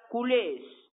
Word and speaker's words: culers: Se escribe culers con R, pero se pronuncia culés.